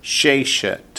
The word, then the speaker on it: sheshet: Sheshet. – Sheshet. – Hashoftim. – Hashoftim. – Hayoshvim. – Hayoshvim. – Ba'ir. – Ba'ir. – And sheshet, accents on the first syllable.